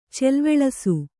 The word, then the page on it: ಚೆಲ್ವೆಳಸು: Kannada